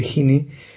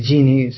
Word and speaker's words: Gini: Desde luego que se puede medir, porque hay algunos que traen a colación, por ejemplo, el término de los índices como el de Gini, o el de Gini, si lo quieres pronunciar así, Famoso.